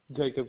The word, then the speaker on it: Jacob: Jacob Casey. – Jacob, it is spelled J-A-C-O-B.